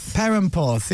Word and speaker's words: Perranporth: Parappenporth. – Is that right? Am I putting an extra syllable in there? – Perranporth, yeah.